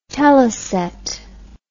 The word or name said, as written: teleset